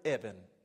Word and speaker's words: Eben: It's the word Eben.